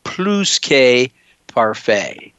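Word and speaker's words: plus-que-parfait: You say the S. I heard a Jenny Reese interview this week. She asked a Frenchman, how do you pronounce it? And it is plus que parfait. So you do pronounce the S.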